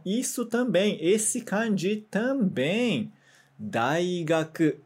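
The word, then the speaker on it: daigaku (大学): Daigaku te yomimasu yo. Yomimasu, se lê. Então, olha, então isso aí também, esse kanji que você viu também se lê. Daigaku, a pronúncia dele é daigaku.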